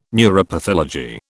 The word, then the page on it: neuropathology: British English